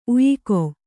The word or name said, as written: ಉಯಿಕೊ